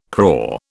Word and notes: craw: British English

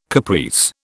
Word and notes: caprice: British English

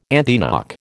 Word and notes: antiknock: American English

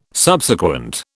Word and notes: Subsequent: British English

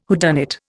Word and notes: whodunit: Indian

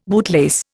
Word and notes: bootlace: Indian

